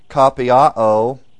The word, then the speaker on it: kopiao: It's pronounced kapiao, K-O-P-I-A-O.